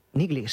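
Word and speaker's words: Neagley: Bueno, pues si os parece, vamos con la siguiente, que es Niggly. ¿Lo he dicho bien? – Niggly, sí.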